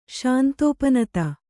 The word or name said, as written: ಶಾಂತೋಪನತ